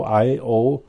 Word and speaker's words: Io: There's actually three icy moons around Jupiter, and the one innermost moon called Io, I-O, it's spelled I-O, pronounced Io, is a very hot moon.